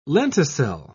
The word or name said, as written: lenticel